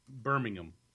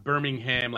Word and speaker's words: Birmingham: But in England, it's pronounced Birmingham, like Birmingham, like it's just how they say it. Birmingham City. It's not Birmingham, like Alabama. It's Birmingham, England.